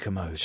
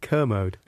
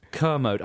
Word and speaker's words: Kermode: How do you pronounce it? – Kermode. – Kermode.